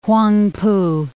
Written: Whangpoo

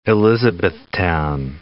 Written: Elizabethtown

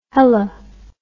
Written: hella

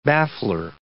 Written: baffler

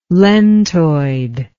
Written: lentoid

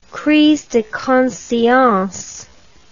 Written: crise de conscience

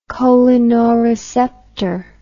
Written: cholinoreceptor

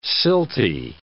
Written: silty

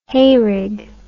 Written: hayrig

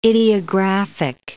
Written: idiographic

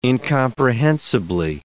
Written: incomprehensibly

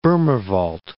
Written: Bohmer Wald

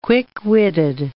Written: quick-witted

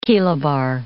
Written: kilobar

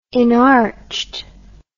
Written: enarched

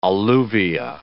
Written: alluvia

